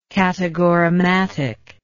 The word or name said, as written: categorematic